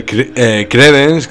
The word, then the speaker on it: Creedence: A ver, credence, que se dice así, bueno, se diría así en su época, es latín en principio y quiere decir creyente o creyendo y tal.